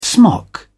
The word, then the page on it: smock: British English